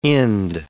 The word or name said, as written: Ind